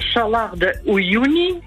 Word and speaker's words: Salar de Uyuni: Ik weet niet of ik het goed ga uitspreken. Salar de Uyuni, ja? – In Bolivia. – In Bolivia. Nou, volgens mij spreek je het helemaal goed uit.